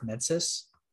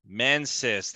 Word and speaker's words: Mensis: The mind of Mendes? No, not Mendes. Is it Mendes? – I never get the name right. – I'm talking about the area Nightmare of Mensis. – Mensis, that's the one.